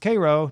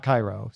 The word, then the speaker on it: Cairo: Berea is in the same land as Cairo, Illinois, which is pronounced Cairo, not Cairo.